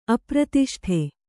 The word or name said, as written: ಅಪ್ರತಿಷ್ಠ